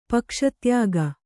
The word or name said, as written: ಪಕ್ಷತ್ಯಾಗ